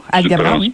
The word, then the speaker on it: Alghabra: Et euh, pendant ce temps-là, le Bloc québécois hier a soulevé des questions sur euh, sur le, nouveau ministre euh, des Transports, Omar Al-Gabra. je ne sais pas si je le prononce comme il faut. – Al-Gabra, parfaitement bien.